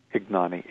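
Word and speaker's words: Ignagni: and I don't know if I have the pronunciation of her last name right, Karen Ignani? – Ignani.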